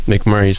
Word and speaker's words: McMurry: Um, McMurry spelled without an A.